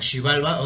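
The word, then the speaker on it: Xibalba: Y como tal no era es bueno, según muchos lo pronuncian como Chivalba, otros dicen que es Chivalba. La cosa es que como tal se ha perdido mucho de todo eso. Ah, situación. no soy maya, no puedo yo decir. – yo, lo pronuncio como Shivalba.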